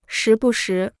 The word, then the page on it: 时不时: shí bù shí